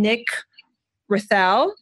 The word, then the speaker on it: Nick Raithel: Today, we're going to welcome Nick Raithel.